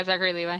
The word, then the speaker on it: Zachary Levi: Zachary Levi? – Yeah, Zachary Levi.